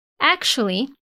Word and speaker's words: actually: Well, actually, essa palavra tem uma pronúncia bem diferente mesmo, tem um sh ali no meio. Vamos falar em partes? Ak, sh, li, actually.